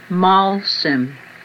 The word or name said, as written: molsem